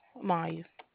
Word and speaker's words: Maya: Maya. – Could you spell that for me? – M-A-Y-A.